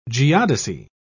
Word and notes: geodesy: British English

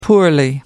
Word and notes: poorly: American English

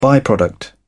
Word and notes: by product: British English

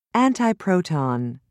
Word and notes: antiproton: British English

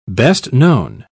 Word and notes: best-known: British English

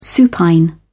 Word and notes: supine: British English

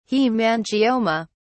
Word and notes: haemangioma: British English

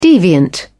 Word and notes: deviant: British English

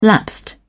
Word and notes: lapsed: British English

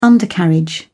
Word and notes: undercarriage: British English

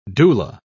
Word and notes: doula: British English

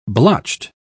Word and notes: blotched: British English

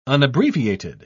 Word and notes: unabbreviated: British English

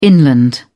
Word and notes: inland: British English

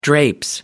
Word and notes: drapes: British English

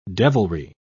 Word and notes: devilry: British English

